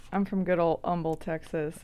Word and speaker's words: Humble: And you know, we don't pronounce the H in Humble. – Correct. – Yeah. That's how you know if it's an outsider. – Well, I was born in Midland. We have a street in Midland named Humble. – Mm-hmm. – And most everybody in Midland pronounces it Humble. – Yeah. – And I was like, well, that's not the way. That's not the way that's said at all.